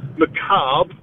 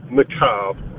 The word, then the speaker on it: macabre: And the word macabre, there you go, that's how you pronounce macabre. Not macabre, it's okay. Macabre.